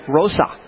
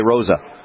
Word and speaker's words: Rosa: Tommy Rosa. I say it like that, but you probably say Rosa. – I say Rosa, yeah. – Yeah, Tommy Rosa.